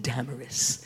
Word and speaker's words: Damaris: And a woman named Damaris. Accent, the syllable is the first syllable, has the emphasis.